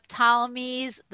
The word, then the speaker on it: Ptolemies: Up until the year 200 before the Common Era, the Ptolemies, it's a silent P, controlled Judea.